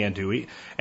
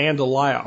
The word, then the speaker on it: andouille: Andouille and andalaya are both proper pronunciations for this sausage depending on the dialect that the sausage is being discussed in.